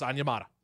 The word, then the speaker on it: Onyemata: Yes, it's pronounced Onyemata.